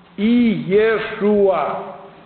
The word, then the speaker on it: Иешуа: Если брать еврейский язык, а правильный арамейский язык, на котором разговаривали в одни Иисуса Христа, это имя произносилось «Иешуа». «Иешуа».